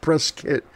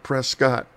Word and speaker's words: Prescott: And it is pronounced Prescott, like a press kit, not Prescott.